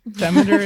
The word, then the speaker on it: Demeter: Is that how you pronounce it? I've been saying Demeter this whole time, but I have literally no idea. Demeter? – I've heard a whole range of them. Demeter is how I usually say it, but I took, like, Greek very, very, very briefly, and don't quote me on that.